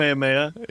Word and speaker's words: Kamehameha: The Kamehameha.